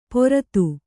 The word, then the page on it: ಪೊರತು: poratu